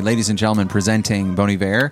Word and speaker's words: Bon Iver: I'm seeing people tweet right now. Is that really how you pronounce it? Bon Iver? – Really? – Just, yeah, they have no idea. They've been saying it wrong for a couple years now.